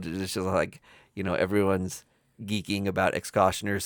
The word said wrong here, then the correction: Executioner's: song and, or Executioner's. – Executioner's song? – Yeah, it's spelled like Executioner. – Executioner. I always like pronounce it stupid.